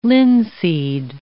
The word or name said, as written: linseed